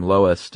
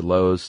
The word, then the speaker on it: loess: And there's actually a few ways to pronounce this. L-O-E-S-S. – Mm-hmm. – I heard everything from Lois to Lowe's to Less.